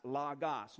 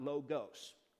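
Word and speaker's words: logos (λόγος): It is not logos. The Greek language has two O-class vowels. You have the omicron, which is represented here, and then you have the omega. The omicron is pronounced ah, the omega is pronounced o. These are omicrons, so we pronounce it as logos, not logos, or logos, or any variation thereof, okay?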